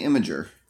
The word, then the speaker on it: Imgur: How does one pronounce that? – I I say Imager. – Imager. Let's go with that.